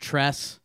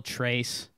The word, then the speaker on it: Trace: His name is supposedly pronounced Tress, but it's spelled Trace.